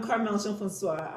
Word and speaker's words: Carmelle Jean-Francois: I'm Carmelle Jean-Francois.